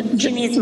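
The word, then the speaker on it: Janeen: Wait, Janine's my name.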